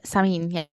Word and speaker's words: Samhain: And a lot of Halloween traditions, especially ones we would know today, are influenced by Celtic harvest festivals, particularly the Gaelic festival Samhain. Am I saying that right? – Yes, I think you are, actually. Samhain, yeah.